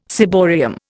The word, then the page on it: ciborium: Indian